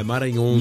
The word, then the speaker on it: Maranhão: A pronúncia é Maranhão? – É, Maranhão.